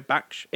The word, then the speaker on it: Baksh: I think it's pronounced that way. Baksh. It's B-A-K-S-H. So it's like saying Baks. Like Sean Connery.